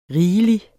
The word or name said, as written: rigelig